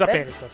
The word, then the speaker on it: Perica: Sì, pronuncia Perizza, sì.